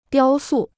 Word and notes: 雕塑: diāo sù

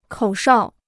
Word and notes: 口哨: kǒu shào

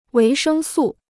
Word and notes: 维生素: wéi shēng sù

